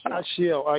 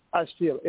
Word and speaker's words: Ashil: It's Ashiel, actually. Ashiel, A-S-H-I-L.